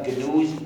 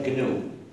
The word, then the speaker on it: GNU: Pero, y en inglés se pronuncia GNU, pero en castellano me han dicho que para ustedes es más fácil pronunciarlo como GNU, como si comenzara por N. Entonces es lo que hago. Pero en inglés es GNU.